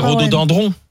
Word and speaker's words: rhododendron: Allez, deux secondes, parce qu'on dit rhodo. – Dindron. – C'est piégeant. – C'est dendron. Alors c'est rhododendron.